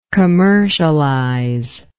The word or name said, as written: commercializer